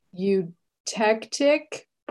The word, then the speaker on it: eutectic: The ice crystal explanation is overly simplistic, great potential, and all has to do with eutectic point of water.